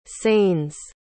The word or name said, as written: sainz